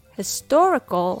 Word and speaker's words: historical: For example, Historical has four syllables. Historical. So when the stress falls on the second syllable, tor, all the other syllables become weak. Historical. Historical.